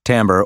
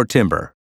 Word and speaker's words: timbre: It's timbre, pronounced timbre or timbre.